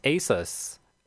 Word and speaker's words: Asus: They're gonna team up with Asus, my bad. That's how you pronounce it.